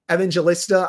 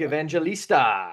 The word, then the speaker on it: Evangelista: Luke Evangelista.